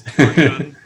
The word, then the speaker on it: torsion: Clear the air on the correct pronunciation of torsion. – Right. Torsion. with an SH sound. I checked English language dictionaries in multiple countries, and not one of them has the ZH sound listed as an acceptable pronunciation.